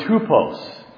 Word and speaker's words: tupos: The Greek word examples here is the word tupos. And I pronounced it for you so that maybe you can hear how it's related and where we get our word type.